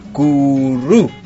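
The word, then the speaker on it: kurü: Se dice curru. – Curru, guachi. – Curru. – Curru. – Sí, es como... Tenés que... – ¿no? Arrastrar la R.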